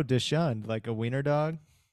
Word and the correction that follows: dachshund: That the word you're saying is pronounced dachshund. – How do you spell it? – D-A-U-S-C-H something, you know, the same word.